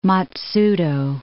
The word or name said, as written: Matsudo